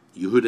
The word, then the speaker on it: Yehuda: Yehuda is the Hebrew pronunciation for the name Judah.